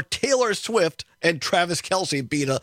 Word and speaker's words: Kelce: Right, yes, Travis Kelce. – I got confused for a second because I was thinking it looks like Kelce, but he pronounces it Kelce.